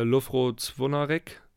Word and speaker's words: Lovro Zvonarek: Äh, Lovro Zvonarek, kroatischer Neuzugang von Slavin Belupo, der da im Zentrum agiert hat auf der doppel Doppelsechs mit Pavlovic. Hast du gut ausgesprochen. Habe ich gut ausgesprochen, hoffentlich.